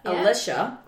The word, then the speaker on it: Alicia: Alicia, right? And it's spelled A-L-I-C-I-A.